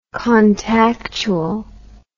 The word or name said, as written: contactually